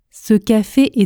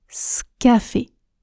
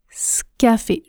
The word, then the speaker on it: ce café: Je prononce scafé. Scafé.